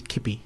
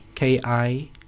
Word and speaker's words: Kippie: My surname is Kippie, Kippie.